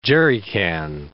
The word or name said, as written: jerry can